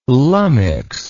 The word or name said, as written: lummix